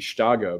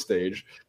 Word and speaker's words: Stage: That's how I remember how to pronounce his name, because you would think it would be Staga, but no. Mm. it is Stage.